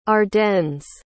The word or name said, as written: ardennes